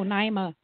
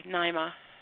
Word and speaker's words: Naima: Yeah, like Naima is more of the Muslim pronunciation. – Okay. – So it's legit, but I'm Naima. – Okay, Naima.